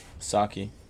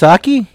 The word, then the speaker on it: Psaki: Psaki. – Psaki? Her name is pronounced Psaki? There's a P in it.